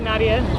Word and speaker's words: Nadia: Hi, Nadia.